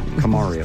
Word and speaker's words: Kamario: K Mario. Kamario. – Think of it that way.